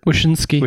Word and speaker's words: Wyszynski: Greg Wisniewski. – Wyszynski. – Wyszynski. I always say his name wrong.